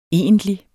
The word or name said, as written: egentlig